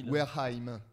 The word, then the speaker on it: Wareheim: bien Eric Warhammer de Team Eric. Warhammer, pas du tout. Ça, c'est Warhammer. – Non mais. Il prononce mal. Non Eric. – Wareheim.